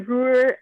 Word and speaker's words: Ruhr: Is it Ruhr? Ruhr? – It is. Yeah, it's the Ruhr.